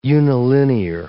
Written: unilinear

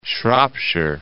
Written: Shropshire

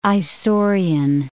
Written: Isaurian

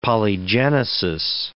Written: polygenesis

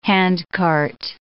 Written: handcart

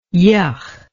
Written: yuch

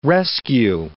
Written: rescue

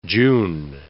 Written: June

